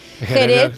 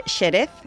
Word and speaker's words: Jerez: Jerez, en principio era con una X, pero ahora se escribe con J, y se pronuncia con J. Nadie dice Xerez.